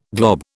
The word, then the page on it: glob: British English